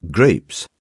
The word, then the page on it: grapes: British English